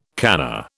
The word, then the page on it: kana: British English